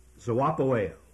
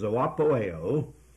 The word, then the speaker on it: zoopoieo: That word is zoopoeo. I'll spell that for these careful students. That spells Z-O-O-P-O-I-E-O. Now that first letter O there, Z-O-O, the first O is the long O, omega, and the last O is omega.